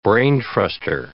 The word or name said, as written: brain truster